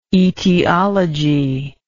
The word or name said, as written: aetiology